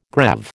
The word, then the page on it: greave: American English